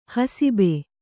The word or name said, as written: ಹಸುಬ